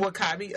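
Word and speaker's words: W'Kabi: And how do you say her husband's name? Makabe? – Wakabi. Okoye and Wakabi.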